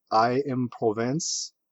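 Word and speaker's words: Aix-en-Provence: I don't even know how to say it in French, so I'll just be terrible, but it's I am Provence, apparently is a big city in France. – You definitely got the I right, yeah, that's how I pronounce.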